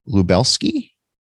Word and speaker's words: Lubelski: they used a traditional Pilsner recipe and sourced Polish Lubelski. Am I saying that right? – Yeah, Lubelski hops to give this beer.